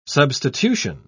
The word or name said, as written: substitution